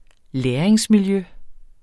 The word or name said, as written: læringsmiljø